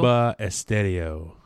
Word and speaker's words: Bomba Estéreo: Bomba Estéreo.